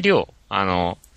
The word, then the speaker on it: 亮: 火星りょう。